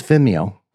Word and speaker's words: Vimeo: How do you say that site? Is Vimeo. it is Vimeo. That's how I say it.